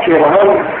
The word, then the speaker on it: پیرون: استدلال این یک فیلسوفی هست به نام پیرون هم بیدون تلفظ می کنن.